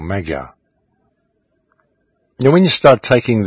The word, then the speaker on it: Magyar: which I believe should more correctly be pronounced Magyar, so perhaps I should start doing that.